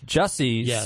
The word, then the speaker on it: Jussie: Jussie Smollett, who is just he was arraigned in a Chicago court for his fake hate crime incident.